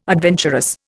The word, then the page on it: adventurous: Indian